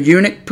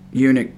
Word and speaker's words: eunuch: Some people get a little confused about eunuch because the U is silent on right after the E. So, capital E-U-N-U-C-H.